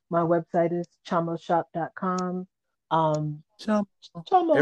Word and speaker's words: Chamo: So Chamo is spelled C H A M O. Chamo. It, it's pretty much pronounced the way it looks.